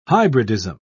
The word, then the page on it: hybridism: British English